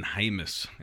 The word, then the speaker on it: Hymus: Again, offensive tackle is a position of need in this recruiting class, and it's actually Brendan Hymus. I think it looks like James or Jameis, but it's Hymus. So I think everyone out there is mispronouncing it.